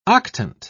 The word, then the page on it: octant: British English